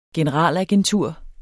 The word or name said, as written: generalagentur